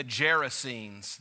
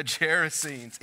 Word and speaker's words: Gerasenes: It rhymes with kerosene, the Gerasenes. There it is, the Gerasenes. It gets me with the G in front.